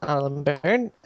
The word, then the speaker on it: Alan Beirne: Well, you can find me on Twitter, where I'm at Alan Byrne. That's spelled A-L-A-N-B-E-I-R-N-E, because it's a different name, you guys, for the love of God.